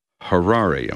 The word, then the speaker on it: Harare: Harare, am I pronouncing that correct? – That is correct. – I'm trying to think of a Ferrari. It's the only way I can do this. Harare, okay.